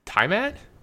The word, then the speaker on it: Tiamat: This is is T-I-A-M-A-T, is it Tiamat?